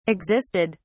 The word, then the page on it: existed: British English